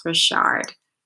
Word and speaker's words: Richard: But my name is spelled Courtnaye and last name is spelled like Richard, but it's pronounced Richard.